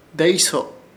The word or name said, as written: Deeyso